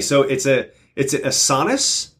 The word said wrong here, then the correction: assonance: or an asinus. – I think it's assonance. – Assonance. I didn't know how to pronounce assonance.